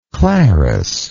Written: clarice

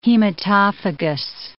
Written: hematophagous

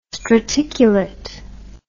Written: straticulate

